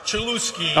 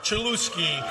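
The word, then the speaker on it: Cholowsky: It's not like it's spelled L-O-U. And you would be like, oh, it's spelled Lau. No. – Select Rock Chalowski. – It's spelled L-O-W like cow. It's spelled L-O-W Chalowski.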